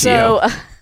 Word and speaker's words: Ezio: It's Ezio. – Ezio.